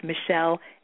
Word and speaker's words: Michele: And my name, my pronunciation of my name is the French pronunciation. It's Michelle Hebert, H-E-B-E-R-T.